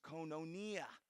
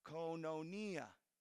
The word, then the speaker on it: koinonia: That word fellowship in Greek is pronounced koinonia. Can you guys say that with me? Koinonia.